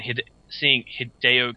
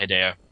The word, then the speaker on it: Hideo: But good try, Hideo. You did a great job either way. – So glad you're actually pronouncing his name right. Because remember, it used to be Hideo? – Hideo.